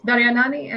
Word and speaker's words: Daryanani: It's Daryanani, and it's hard for everyone, so don't worry about it.